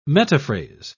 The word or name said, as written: metaphrase